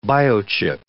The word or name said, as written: biochip